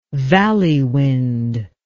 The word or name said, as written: valley wind